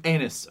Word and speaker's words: anise: Have star anise, anise. – Okay, anise. I think it's pronounced. – You're right.